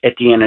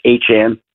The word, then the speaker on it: Etienne: He changed the pronunciation of his name. I'm not mispronouncing it. Not Travis Etienne anymore. It's Travis Etienne.